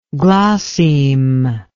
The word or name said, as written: glossemic